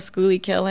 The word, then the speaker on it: Schuylkill: I figured out how to say the Schuylkill. This is a big milestone in my in my Philly tenancy, not to call it the Schuylkill anymore.